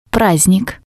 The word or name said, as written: prasdnik